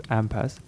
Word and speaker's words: AMPAS: And he said in brackets, it's pronounced AMPAS.